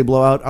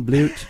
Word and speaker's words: Blowout: Some people say blowout. I like the Canadian pronunciation, Blute.